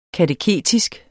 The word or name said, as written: kateketisk